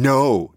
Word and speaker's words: know: Yeah, so before, you might have thought that I was saying no, K-N-O-W. I was actually saying no, Jenny, as in no.